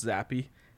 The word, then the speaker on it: Zappe: Are we sure it's pronounced Zappe? – Yeah, I looked it up.